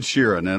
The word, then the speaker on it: Sheeran: Uh, from January uh, 2, from in other words, from right now through the 16th, Ed Sharan and all, uh, if I'm pronouncing that correctly, and all Sharon, thank you, Mike. Mike stood all he could. Man, he sounded insistent in my headphones. All right, Ed Sheeran and all the other adult dogs and cats.